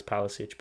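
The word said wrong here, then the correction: Pašalić: but Pasalic.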